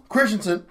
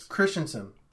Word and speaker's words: Christensen: Hayden Christian did a great job in that role. – Hayden who? – Christensen. – Thank you. Please say his name correctly. He said Christian the first two times. – Christensen.